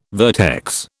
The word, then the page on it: vertex: British English